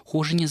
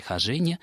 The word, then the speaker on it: хожение: Профессор московской аспирантуры, где она в свое время училась, произносил это слово с ударением на втором слоге «хожение».